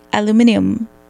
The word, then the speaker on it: aluminium: 哇哦，对，所以 aluminium。 foil，英国人应该也会叫那个 foil，aluminum，aluminum，对，那是美式发音。